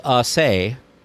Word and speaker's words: assay: So it's probably not actually pronounced like we are pronouncing it in our minds. – Well, it's pronounced, or there's assay. So yeah. Assay. It's the assay office.